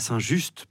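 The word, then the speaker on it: Saint-Just: Quant à Saint Just, prononcé Saint Ju par les Lyonnais, c'est le 13e évêque de Lyon au 4e siècle.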